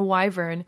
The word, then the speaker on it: wyvern: I don't know how that word is pronounced. – I always say wyvern. – A wyvern, who essentially feels a lot like a cat, just a very exuberant and loving cat who just really loves your attention.